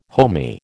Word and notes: homy: American English

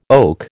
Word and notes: oak: American English

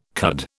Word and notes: cud: British English

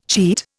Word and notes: cheat: Indian

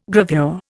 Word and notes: gravure: Indian

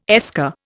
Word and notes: esker: Indian

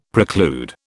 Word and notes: preclude: British English

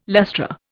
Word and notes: lustra: Indian

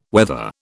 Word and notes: wether: British English

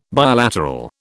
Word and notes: bilateral: British English

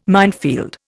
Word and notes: minefield: Indian

